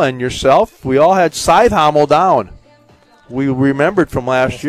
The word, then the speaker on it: Cajthaml: C-A-J-T-H-A-M-L. Scythhommel is how they pronounce it.